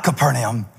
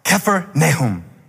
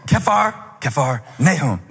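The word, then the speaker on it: Kfar Nahum: He said, not Capernaum, Kephernahum. Took me seven times to say it. And till he was satisfied, he said, Kephar, Nahum. Kephar, village. Nahum, comforter. Like the minor prophet, Nahum. He said, say it again. Kephar, Nahum. Kephar, Nahum. Stress on the K, Kephar, doesn't matter. What matters, what it means, village of the comforter.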